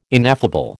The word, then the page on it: ineffable: American English